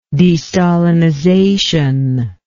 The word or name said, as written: destalinization